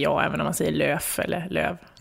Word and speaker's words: Lööf: Jag hörde en intervju med dig där var någon som kallade dig för Annie Lööf också. Är det jobbigt? – Ja, alltså L-Ö-Ö-F ska ju egentligen uttalas Lööf, men jag säger ju Lööf.